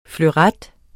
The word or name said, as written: fleuret